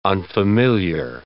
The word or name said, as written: unfamiliar